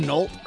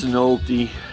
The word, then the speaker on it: Nolte: All right, do you say his name Nolt, or is it Nolte? N-O-L-T-E? – I think it's Nolte.